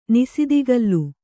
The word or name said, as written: ನಿಸಿದಿಕಲ್ಲು